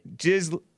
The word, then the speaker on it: Ghislaine: Ghislaine. Okay, that sounds better. Because I don't, you know, it just doesn't sound right. – The S is silent.